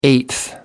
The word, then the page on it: eighth: American English